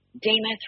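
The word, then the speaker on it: Demuth: Damuth rhymes with Namath. – See, I've always she called her Damuth, but there were a bunch of pundits going, Damuth.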